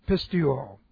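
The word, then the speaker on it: pisteuo: Aorist active indicative of pistuo. Okay. P-I-S-T-E-U long O.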